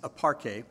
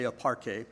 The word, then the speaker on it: aparche: And in secular use of this word, by the way, and the word is aparche, and actually that's not the correct pronunciation of the word, but if I were to try to do the correct pronunciation, I'd be spitting on half of the congregation, okay? So we'll just say aparche.